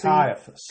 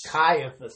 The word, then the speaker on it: Caiaphas: Caiaphas. – Caiaphas, that's right.